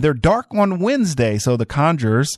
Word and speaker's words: The Conjurers: It's called The Conjurers. Yeah, that's how you say it.